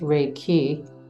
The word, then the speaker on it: Reiki: Reiki, pronounced Reiki, means spiritually guided universal life force energy in Japanese.